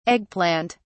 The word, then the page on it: egg plant: British English